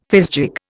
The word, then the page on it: fizgig: Indian